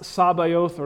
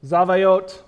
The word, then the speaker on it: Sabaoth: And so it is pronounced Sabaoth or something similar to that. I spell it S-A-B-A-O-T-H. Sabaoth, Zavayot. It's somewhere in that pronunciation.